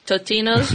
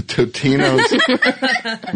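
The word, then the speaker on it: Totino's: Totina. – No. – Totinos. – Totinos.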